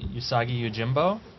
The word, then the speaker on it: Usagi Yojimbo: And add Stan Sakai on Usagi Yojimbo. Did I pronounce that correctly? – Mm-hmm. You did it. Way to go.